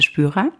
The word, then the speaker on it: Spyra: Ja, mein Name ist Henriette Spürer.